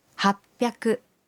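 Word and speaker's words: happyaku: Y 800 se dice hapyaku.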